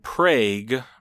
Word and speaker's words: Prague: And they pronounce it Prague, but we would... It looks like Prague, Oklahoma.